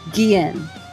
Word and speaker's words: Guillen: Guillen. So like the L's are silent, basically. Guillen. – Yes. – Yeah. It's like skiing. But Guillen.